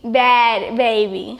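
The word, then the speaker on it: Bhad Bhabie: Baby, bad baby. Same way you would spell it. Say it the same way you would spell it regularly. B-A-D-B-A-B-Y, bad. It's just I spell it different.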